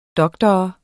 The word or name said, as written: doktor